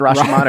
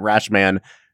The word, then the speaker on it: Rashomon: And every time you say it, I'm like, do I add the context that Brad knows that it's actually pronounced Rashomon, or do I just let him pronounce it Rash Man and add nothing to that? – I think you just leave it as is.